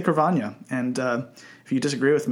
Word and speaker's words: Carvanha: So you might expect this to be pronounced pronounced Carvana, right? – Yeah. – That's probably how most people pronounce it. But the NH, when I look at it, is very Portuguese sounding. And in Portuguese, it has the equivalent of an Enye sound from Spanish. So okay. Carvana.